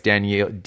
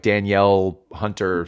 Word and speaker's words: Danielle Hunter: I think Jordan played better today than he did last week, and he was playing against Danielle Hunter. I can't. It's a weird pronunciation. It's not Daniel. It's a weird like Danielle Hunter sound.